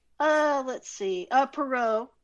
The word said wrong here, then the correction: Poirot: of course. Wait, I can't pronounce it. – Poirot. – Poirot.